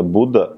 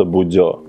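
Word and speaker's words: Bodø: Вот норвежцы говорят именно Будда, а по-русски, если посмотреть, почему-то Будё.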